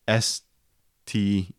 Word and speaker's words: Stein: But like in German, IE is always pronounced E, and IEI is always pronounced I, right? STEIN is always Stein. It's always Stein. But in English, it could be either.